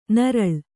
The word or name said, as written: ನೀರೆಳ್ಳು